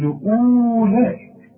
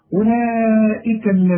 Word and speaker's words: أولئك: شوف أولئك اوعى تقول أولئك لا كأن الواو غير موجود. أولئك الذين ما فيهاش مشاكل اهي لأنها مرققة.